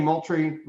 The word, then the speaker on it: Moultrie: I'm am I saying Moultrie wrong too, Jay? – JR? No, you got it. You, you got it. I think. All right. I didn't know I'm, extra O and Moultrie or how that was.